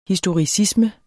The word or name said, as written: historicisme